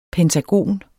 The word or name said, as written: pentagon